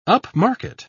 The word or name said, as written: upmarket